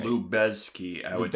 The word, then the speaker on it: Lubezki: And also, of course, I have to shout out the cinematography, which is done by Emmanuel Lubezki. I believe I'm pronouncing that right. – Lubezki.